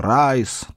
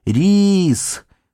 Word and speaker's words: Рис: Они даже не знали, как толком произносить его имя, и называли его то Райс, то Рай, то Райз. Правильно говорить «Рис», не уставал повторять им Рис.